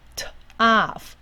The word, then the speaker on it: tough: 好，最后在字尾大部分是念辅的音，rough rough rough tough tough tough。